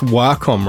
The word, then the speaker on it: Wacom: So it is pronounced Wacom, right? – Yes, it comes from the word wa in Japanese, that means harmony.